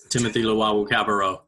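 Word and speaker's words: Timothy Luwawu-Cabarrot: Timothy Luwawu Cabarro.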